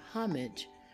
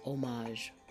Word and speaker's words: homage: This word can be pronounced with an H sound, homage, or without the H sound, which is homage.